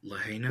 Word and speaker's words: Lahaina: Lahaina.